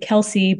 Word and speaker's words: Kelce: That uh, Travis and his brother pronounce it Kelsey, but the father pronounced it Kels.